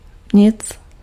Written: rien

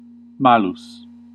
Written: wicked

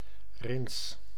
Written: vert

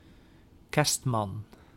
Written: Santa Claus

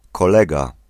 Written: fellow

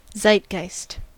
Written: zeitgeist

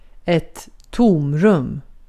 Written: tomrum

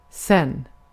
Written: geç

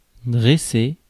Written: stand

